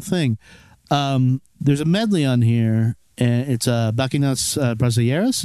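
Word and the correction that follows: Bachianas Brasileiras: Bahianas Brasileiras, which is a classical piece composed by Heitor Villa-Lobos, who's like the famous Brazilian composer.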